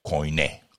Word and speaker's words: koiné: Además de su natal griego koiné. Koiné se escribe K-O-I-N-E con un acento en la E.